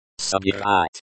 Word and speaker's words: Собирать: The stress in. Собирать. Собирать is on the second syllable.